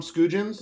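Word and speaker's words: Skujiņš: Your winner for stage three was, is it Tom Skujens? Did I say that right? – Correct.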